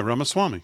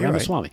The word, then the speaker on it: Ramaswamy: and Hammer rival Vivek Ramaswamy. Uh, uh, am I pronouncing that right? Yeah, Ramaswamy. – Okay, yeah, Ramaswamy.